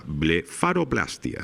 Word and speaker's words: blefaroplastia: Blefaroplastia. ¿Lo he dicho bien, doña Cristina? – Perfecto.